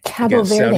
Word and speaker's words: Cabo Verde: Group H sees Cape Verde or Cabo Verde or however you want to pronounce it. – Cabo Verde.